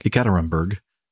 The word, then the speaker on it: Ekaterinburg: Red Wings A321 near Ekaterinburg. Is that the way you pronounce that? – That's how I would pronounce it. – Okay. – Ekaterinburg. – Ekaterinburg.